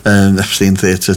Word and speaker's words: Epstein Theatre: So after the show later, I've got to go to the Epstein Theatre, British pronunciation, Epstein Theatre to go and see David Knopoff playing the Man Behind the Voice, which is all about Frank Sinatra.